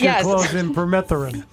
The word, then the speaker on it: permethrin: I say that right? Yes, you did. Close. Okay. so that would be a no. It's permethrin. Permethrin.